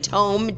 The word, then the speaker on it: tome: And it directly lines up with the passage in the Holy Tomb. Wow. And by tomb, I mean tome, T-O-M-E. Sorry about that. Said that wrong.